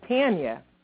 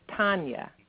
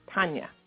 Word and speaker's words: Tanya: And, of course, in Uganda, you guys would pronounce my name Tanya. – Tanya. – But it's Russian, actually, so it's Tanya. Tanya. Yeah.